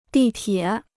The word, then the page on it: 地铁: dì tiě